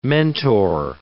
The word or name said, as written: mentor